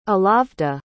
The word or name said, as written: alavda